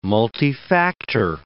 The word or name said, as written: multifactor